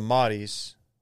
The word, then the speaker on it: Modis: There's a Modis. It's M-O-D-I-S, and apparently it's pronounced Modis.